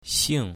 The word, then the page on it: xing4: mandarin